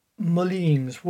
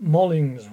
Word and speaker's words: Moling: And it was St. Moline's Well. That's what we used to pronounce it as. M-O-L-I-N-G. It was only afterwards, when I left the parish, that it was known as St. Molling's Well, I was told.